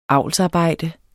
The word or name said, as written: avlsarbejde